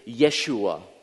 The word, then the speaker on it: Yeshua: The name Jesus is the Greek or Hellenized version of the name Joshua, or if you were to pronounce it more accurately, it's Yeshua, Y-E-S-H-U-A, Yeshua.